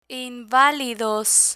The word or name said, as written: invalidos